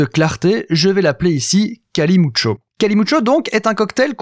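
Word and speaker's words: calimocho: Alors je prononce Kalimucho car c'est comme cela que l'on me l'a présenté la première fois,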